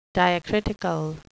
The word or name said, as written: diacritical